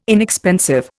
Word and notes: inexpensive: Indian

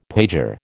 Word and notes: pager: American English

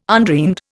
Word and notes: undreamed: Indian

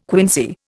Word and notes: quinsy: Indian

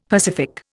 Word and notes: pacific: Indian

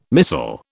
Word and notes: missal: British English

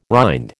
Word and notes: rind: American English

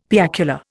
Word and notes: piacular: Indian

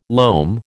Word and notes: loam: American English

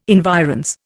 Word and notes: environs: Indian

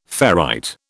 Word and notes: ferrite: British English

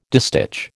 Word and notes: distich: American English

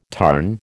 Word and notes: tarn: American English

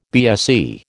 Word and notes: BSE: American English

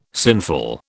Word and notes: sinful: British English

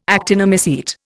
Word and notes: actinomycete: Indian

